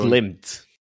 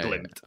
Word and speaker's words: Glimt: Glimt. – There you go. – Yeah. Glimt.